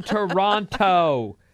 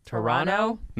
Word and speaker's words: Toronto: Stop saying Toronto. Use a soft T at the end. Toronto? – No, you're supposed to say it like this.